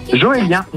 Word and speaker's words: Joélien: Ça se prononce comment ton prénom? – Joélien. J-O-E-L-I-E-N.